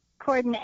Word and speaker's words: Corydon: Yes, Battle of Corden. And it's spelled C-O-R-Y-D-O-N. You don't pronounce the Y, but if you don't put it in there, you're not going to get to the site.